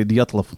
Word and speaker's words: Dyatlov: So the Dyatlov, I don't know if I'm saying it right. Dyatlov, how would you say? You're an expert in Russian. – I would say Dyatlov.